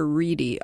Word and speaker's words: Wrede: And it's Patricia Reed, W-R-E-D-E. – And I've heard that pronounced Patricia Reedy, although I'm not convinced. I mean, I wouldn't say that it's one way or another.